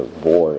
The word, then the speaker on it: boar: You know, that's what a boar is. It's pronounced the same way, but it's written differently. B-O-R-E or B-O-A-R.